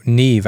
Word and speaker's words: Neve: Neem Algar, Neve, I think is how she pronounces it.